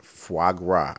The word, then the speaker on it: foie gras: Foie gras. I looked up the pronunciation.